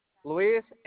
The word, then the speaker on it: Luis: My first name, Luis, last name, Mendez. – Can you spell that for me? – Luis, L-U-I-S, Mendez. – L-U-I-S? – Yes. My last name is Mendez. Mendez, it's M-E-N-D-E-Z.